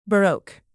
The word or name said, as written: baroque